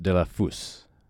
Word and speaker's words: Delafose: I was saying Geno Della Fos. It's it's actually pronounced Della Fus. Okay, so hopefully that's that's a little closer.